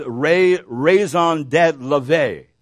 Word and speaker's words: raison d'être: It's the French say it like this, his raison d'être.